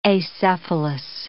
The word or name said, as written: acephalous